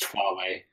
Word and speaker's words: Huawei: So it is a Yahua 937. – It's pronounced Huawei.